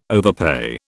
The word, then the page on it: overpay: British English